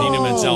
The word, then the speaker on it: Idina Menzel: Oh, that's pronounced like that. When I mentioned that, Menzel, thank you.